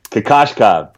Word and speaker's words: Kokoshkov: I butchered the F out of that name. – Kokoshkov. You got it a couple episodes back.